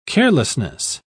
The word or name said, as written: carelessness